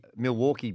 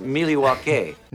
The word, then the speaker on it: Milwaukee: Well, you asked me where the upset was going to come from, and it seems like the Milwaukee Bucks. – Actually, it's pronounced Milwaukee.